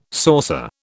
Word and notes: saucer: British English

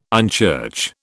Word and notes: unchurch: British English

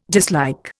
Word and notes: dislike: Indian

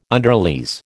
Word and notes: underlease: American English

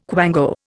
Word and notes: quango: Indian

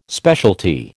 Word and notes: specialty: American English

